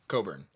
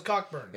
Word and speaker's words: Cockburn: Coburn. It is spelled Cockburn. It is Coburn. – I definitely thought it was Cockburn.